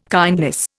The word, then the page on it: kindness: Indian